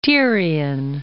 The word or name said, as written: Tyrian